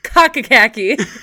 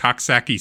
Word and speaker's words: Coxsackie: Cockacackie. Cockacackie. – Coxsackie. Hold on. You don't pronounce the X.